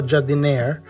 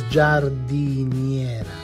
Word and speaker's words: giardiniera: Well, that for sure is not Giardiniera, but it's Giardiniera.